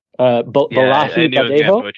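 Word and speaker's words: Bolaji Badejo: This one I'm unfortunately probably going to mispronounce. Bojea Banjo. – Hang on. The gentleman who played the alien himself, it's Nigerian actor, Balahi Badejo, I believe.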